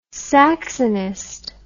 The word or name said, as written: saxonist